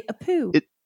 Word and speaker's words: EPU: The letters or do you say a poo? – A poo. Like Ipoo. Like it's electronic Ipoo. The EPU. Let's just call it the EPU.